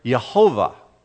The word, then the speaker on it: Jehovah: Lord there is not Adonai, it's Lord Jehovah. That's the way it's pronounced in the Hebrew.